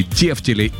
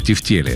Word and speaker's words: тефтели: На самом деле, вы не поверите, но два варианта ответа считаются верными. Можно говорить и тефтели, и тефтели. Есть, конечно, справочник русское словесное ударение имени Зарвы. Он рекомендует ударение на второй слог, в тефтели.